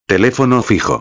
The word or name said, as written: telefono fijo